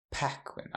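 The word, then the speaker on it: Paquin: Eller kan aldrig uttala hennes namn. Är du, du är bra på uttal? nu, nu vill vi höra. Packwin. Packwin, ja.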